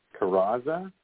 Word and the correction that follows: Carezza: Carezza. – Carezza. – It's Italian.